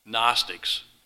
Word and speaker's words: Gnostics: That's spelled Gnostics. Gnostics. The G is silent.